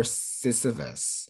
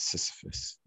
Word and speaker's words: Sisyphus: And this is episode 4, Poor Sisyphus. I hope I said that right. – Yes, Sisyphus.